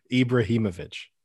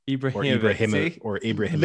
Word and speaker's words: Ibrahimovic: Ibrahimovic. – Ibrahimovic. – Literally say the Ibrahimovic. – Syllables. – Ibrahimovic.